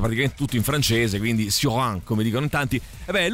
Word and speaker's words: Cioran: Allora, senti un po', lo sai che è uscito, è riuscito per Adelphi in questi giorni un libro di Emil Cioran, o Cioran, che dir si voglia, che lui era uno scrittore rumeno, quindi se la pronunciamo la rumena è Cioran, altrimenti siccome lui è andato...